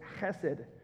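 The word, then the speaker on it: chesed (חסד): This word here is chesed. You have to sound like you're trying to hawk up a loogie when you say it. Chesed is how that word is pronounced.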